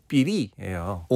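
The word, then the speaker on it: 삐리 (觱篥): 근데 이 필률을 중국 발음으로 오늘날의 중국 발음으로 하더라도 네. – 삐리예요.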